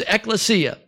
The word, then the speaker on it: ekklesia: It's the Greek word. You know, I'm doomed from the beginning. It's ekklesia, as Pastor Michael just said.